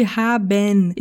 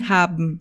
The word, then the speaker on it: haben: Perceba que em todas essas palavras schnecken, erschrecken e schlecken, o E antes do N não é pronunciado. Por isso não fale haben e sim haben.